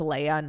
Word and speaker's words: Leia: Like, oh, um, instead of by the way it is pronounced leia not leah oh like like the princess kind